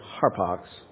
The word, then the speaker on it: harpax: We would pronounce it with an H, harpax.